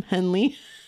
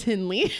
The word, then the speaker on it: Hindley: Do you realize that this whole podcast we've been calling him Henley? – Well, it's spelled Hindley. – It is Hindley.